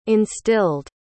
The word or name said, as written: instilled